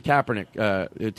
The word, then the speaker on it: Kaepernick: So with the I had a thought on the Kaepernick taking the knee, you know.